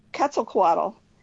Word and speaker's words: Quetzalcoatl: It's Quetzalcoatl.